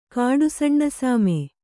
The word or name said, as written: ಕಾಡುಸಣ್ಣಸಾಮೆ